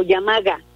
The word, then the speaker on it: yamaga: Y luego, cuando ya me voy, digo yamaha, ¿no? Ok. Yamaya, ¿no? Yamaya o yamaga. Yamaga, yamaga, para pronunciarlo bien.